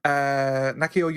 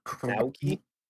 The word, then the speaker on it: Naoki: So during the media event, the press tour for Endwalker, Nakio Yoshida, the Naoki.